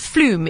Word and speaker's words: Floome: Just in closing, James has made a very, very generous offer to give away to it's Floome is the correct pronunciation.